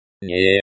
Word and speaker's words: Не: Не. Не. Say it like nay with the e sounding like the e in the.